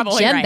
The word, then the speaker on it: djembe: Is it pronounced djembe? – That's how I've heard it, but it's duh, djembe. – Right. Djembe.